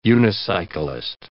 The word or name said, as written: unicyclist